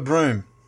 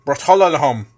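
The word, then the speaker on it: Bruttenholm: But moving on, we're introduced to more prevalent heroes in this Hellboy U, which is Trevor Broom, or Bratolahom. As if you were to read it from the way they actually write. Luckily, from this journal entry, the captain luckily pronounced Broom in his own journal entry.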